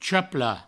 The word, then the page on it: chaplar: chapla; provençal